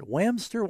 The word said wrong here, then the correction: Wamsutter: Wyoming? Brandy, you're on the air. Huh? – Wamsutter, Wyoming. – Wamsutter. – Sorry.